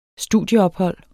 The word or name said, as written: studieophold